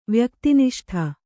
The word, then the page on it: ವ್ಯಕ್ತಿನಿಷ್ಠ: vyakti niṣṭha